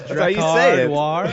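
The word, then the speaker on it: Drakkar Noir: You know how you pronounce Dracar Noir? Those long A's?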